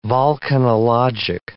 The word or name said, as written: volcanologic